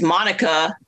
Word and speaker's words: Monica: which is Monica, M-O-N-I-C-A. I almost spelled my name wrong. – Like Miami. – M-O-N-I-C-A.